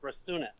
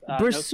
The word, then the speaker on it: Bresunas: Bresunas. Bresunas. No silent letters. Just Bresunas.